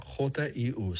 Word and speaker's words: HU: La palabra Hu se escribe Hu. Se pronuncia Jiu. Suena así.